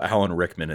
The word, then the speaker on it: Rickman: Plus, we had an Alan Rick. I can't say his name. Excuse me. We have the wonderful Alan Rickman in this movie, and I miss him every day now because, oh, it's so sad.